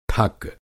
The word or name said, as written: ठक